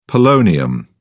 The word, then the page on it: polonium: British English